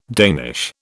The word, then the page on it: Danish: British English